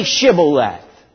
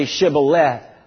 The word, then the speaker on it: shibboleth: But if you can't say it because I think it was the Ephraimites weren't able to say it right, they would say shibboleth. They couldn't say the TH.